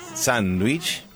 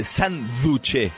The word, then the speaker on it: sándwich: Después están los que le metemos, después de la N del san, la G, que ahí viene sándwich o sándwich, que es una mezcla entre la versión original de la palabra, que es sándwich, pero escucharán otras pronunciaciones a la hora de viajar por el mundo. Hay gente a la que al sándwich le dice sanduche, con D, o sanduche, bocadillo en España, torta en México, emparedado.